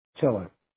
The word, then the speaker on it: Tiller: Tiller. T-I-L-L-E-R. Tiller. – Tiller, gotcha. – Like, okay. – Yeah, like the boat.